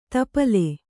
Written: ತಿಪ್ಪಲಿ

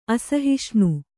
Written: ಅಸಹಿಷ್ಣು

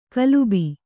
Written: ಕಲುಬಿ

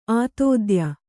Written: ಆತಿಥೇಯಿ

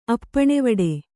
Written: ಅಪ್ಪಣೆವಡೆ

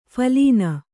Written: ಫಲಿನಿ

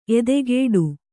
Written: ಎದೆಗೂಡು

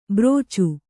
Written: ಭರ್ಚಿ

